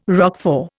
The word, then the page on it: Roquefort: Indian